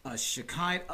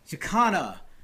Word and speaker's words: Chicana: She is a Shekinah. Oh, man, I don't want to say it wrong. – Chicana? – Chicana.